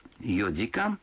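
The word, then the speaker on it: йодикан (四時間): Некоторые цифры в этих случаях произносятся не так, как обычно. 4 часа – это йодикан, 7 часов – ситидикан, а 9 часов – кудикан.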